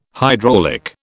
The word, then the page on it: hydraulic: British English